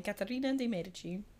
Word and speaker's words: Caterina de' Medici: she's the one we're talking about, or as you would say her name in Italian, Caterina de' Medici.